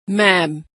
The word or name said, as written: ma'am